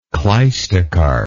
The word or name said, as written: clistocarp